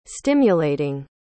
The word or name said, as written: stimulating